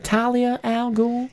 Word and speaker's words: Talia al Ghul: Am I saying that right? Talia al Ghul? – Yeah. – Go ahead. – Anyway. No, I'm sorry. It's Raish. – It's Talia.